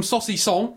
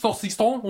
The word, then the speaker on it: saucisson: But as this bleeding podcast moves even more into ridiculousness, I'm eating some saucisson. Is that how I pronounce it? – Yeah. – Some French saucisson, all the way from sausage.